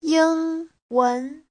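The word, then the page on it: yingwen: ying1wen2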